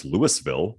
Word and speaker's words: Louisville: Okay, well, it's in Colorado. It's pronounced Louisville.